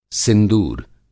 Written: सिंदूर

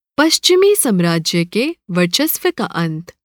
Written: साम्राज्य

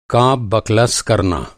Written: बकलस

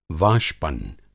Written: वाष्पन

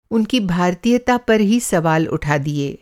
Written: भारतीयता

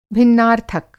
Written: भिन्नार्थक

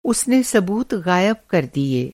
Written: ग़ायब

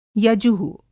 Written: यजु:

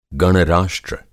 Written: गण-राष्ट्र

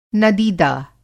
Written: नदीदा

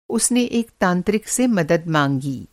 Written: तांत्रिक

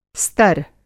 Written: स्तर

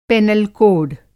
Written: पेनल कोड

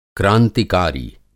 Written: क्रांतिकारी

